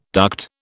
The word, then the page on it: duct: British English